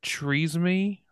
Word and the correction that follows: Renesmee: from the Twilight Breaking Dawn part. – It's Renesmee.